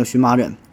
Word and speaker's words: 荨麻疹: 比如说这个荨麻疹啊，现在咱都管叫荨麻疹，草字头下边一个寻找的寻叫荨麻疹。